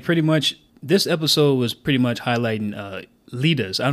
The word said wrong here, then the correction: Idia: Idia. It's not an L, it's an I. Okay. It's a capital I.